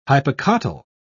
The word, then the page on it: hypocotyl: British English